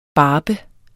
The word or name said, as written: barbe